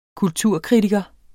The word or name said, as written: kulturkritiker